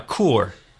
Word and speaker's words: Kuhr: Tell me how to say this right, okay? – Kuhr. – Kuhr. – Yeah. Like Kuhr, like Coors beer, okay. – But without the S.